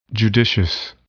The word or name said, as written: judicious